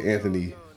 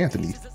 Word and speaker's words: Anthony: See how that rhymes? It's like my name, God made Anthony to rhyme with an introduction every time. So I say, hey, it's me, Anthony.